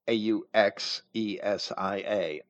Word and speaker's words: Auxesia: Auxesia a U X E S I A, Auxesia, kind of difficult for me to pronounce,